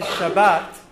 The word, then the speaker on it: Shabbat: Okay, the variation between Shabbos and Shabbat is really a difference in pronunciation of Hebrew between Sephardic and Ashkenazic Jews.